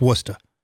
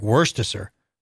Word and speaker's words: Worcester: It's not, it's, it, you don't pronounce the R in it. It's Worcester. Worcester. Promise you. – But that's how people there talk. – No, I mean, they all say, no, because if you, it's very different. Because if you did it how it was spelled, it'd be like Worcestershire, which it's not that.